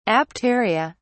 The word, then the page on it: apteria: British English